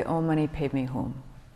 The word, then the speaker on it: Om Mani Padme Hum: So I say Om Mani Padme Hum.